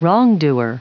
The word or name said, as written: wrongdoer